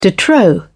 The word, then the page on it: de trop: British English